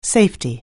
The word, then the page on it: safety: anglais